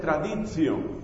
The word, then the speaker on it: traditio: Entonces, la palabra tradición, que en latín se dice traditio, con T, traditio, y se pronuncia traditio, y que en griego se dice paradosis.